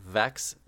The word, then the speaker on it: Vex: Yeah, Vex, V-E-X, Madam Vex.